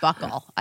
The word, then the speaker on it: buccal: It's called pronounced buckle, apparently.